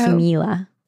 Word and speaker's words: Kimila: It's spelled K-I-M-I-L-A because Indrid's wife's name was Kimi. So it's Camila.